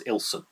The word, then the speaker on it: Ilkeston: And the nearest town to where I am here is called Ilkeston, which has got a silent K. It's actually pronounced Ilson.